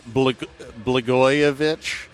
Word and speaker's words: Blagojevich: I don't know how to say this guy's name. Blagojevich. – Yeah, that's good. – Blagojevich. – You had it the first time.